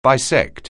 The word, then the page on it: bisect: anglais